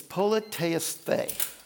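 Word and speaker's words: politeisthē (πολιτεύεσθε): Now, I'm going to pronounce the word in Greek so that you can hear it because it's a Greek word that's root comes over to our language a lot, okay? It's politeisthē.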